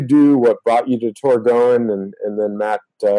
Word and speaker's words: Torgoen: Uh, and you're right, we do get a bunch of different uh, uh, responses to the name, but the official uh, name is pronounced Torgoen.